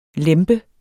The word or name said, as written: lempe